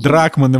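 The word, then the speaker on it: Druckmann: Дрюкман, как его фамилия правильно читается, наверное, ты лучше меня знаешь. Дрюкман. Дрюкман. Дрюкман, мне кажется. Нил Дрюкман. Дракман его фамилия.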